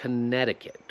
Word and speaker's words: Connecticut: Nie, to jest Connecticut. Tutaj nie chcę, żebyś nawet patrzył na to, jak się pisze Connecticut, bo dlatego będziesz źle to wymawiał. Jest lepiej, żeby słyszeć, jak inni ludzie to wymawiają.